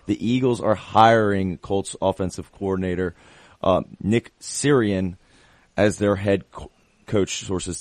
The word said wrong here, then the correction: Sirianni: You missed a couple of vowels. You called him Sirian. – Sirianni. Sirianni, yeah, my apologies. – Sirianni.